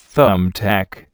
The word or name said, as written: thumbtack